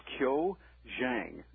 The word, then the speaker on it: Zhang: His name is pronounced Qiu Zhang. – Okay. – Z-H-A-N-G. Zhang. It's a popular Chinese name.